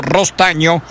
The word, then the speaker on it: Rostagno: Gonzalo Rostagno se escribe, se pronuncia Gonzalo Rostaño.